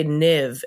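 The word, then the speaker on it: Niamh: But still you're like, why did they say Niv? And you look online and it's spelled like N-I-A-H-M-N.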